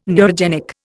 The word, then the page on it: neurogenic: Indian